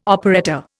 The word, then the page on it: operetta: Indian